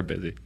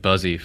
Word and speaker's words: Busy: That's why they call her Busy. Just Busy Hands. – Yeah, that's right. Yeah, she gets busy. – Yeah. And I was pronouncing it Buzzy for years.